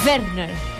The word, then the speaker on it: Werner: Klaus Werner, perquè Werner. Es pronuncia així. – Werner. – Com el Lluís Llach ho diria. Werner.